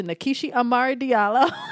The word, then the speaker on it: Nkechi Amari Diallo: It is Sister Nkechi Amari Diallo.